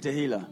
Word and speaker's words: Tehila: Let me hear you say Tehila. – Laten jullie allemaal Tehila zeggen. – Say it like a Mexican.